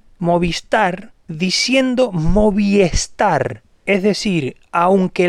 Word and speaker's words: Movistar: Es decir, no debería haber ninguna dificultad de pronunciación para que el español pueda pronunciar correctamente Movistar. Hay una I delante de star, es decir, la regla se cumple perfectamente. Sin embargo, el 99% dice Movistar. No hay una E en la marca Movistar.